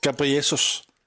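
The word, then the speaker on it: Gabriel Jesus: Nej, han säger Gabriesus. Det är en uttalsfråga. Ja, och då ska det uttalas rätt om det ska ge rätt poäng.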